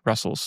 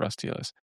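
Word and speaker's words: Rustls: And so I started to implement this Qwik API that we needed for, that I needed for Qwin's TLS aspects in Russell's. So we pre-pronounce it Russell's instead of Rust TLS.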